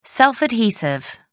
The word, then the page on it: self adhesive: American English